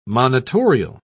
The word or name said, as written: monitorial